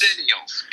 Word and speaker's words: Xennials: Lamely enough, the X is pronounced hard. – Oh, so it's not, like we're not Xennials, we're Xennials. – Xennials, because of Generation X.